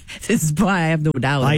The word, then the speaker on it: No Doubt: Alors, c'est confirmé, c'est No Doubt.